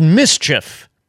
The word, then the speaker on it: MSCHF: They're made by a company called Mischief. It's spelled M-S-C-H-F, Mischief. It's a Brooklyn-based art collective.